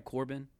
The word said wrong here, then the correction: Corden: What's his name? Corden. – Corden. D. It's D, yeah.